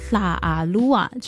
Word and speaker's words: 拉阿鲁哇: 讲到拉鲁哇族呢，其实这个汉字叫拉阿鲁哇，但是呢他们的发音并不是叫拉阿鲁哇，而是叫拉阿鲁哇，就是它是用一个有点舌尖音去发音的。